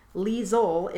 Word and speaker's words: Liesel: And then I saw how it was spelled and thought, well, I must be incorrect because obviously L-I-E is lie in English. So she was very kind and told me it's Liesel is how she pronounces it, even though some Americans pronounce it Liesel with an S instead of a Z.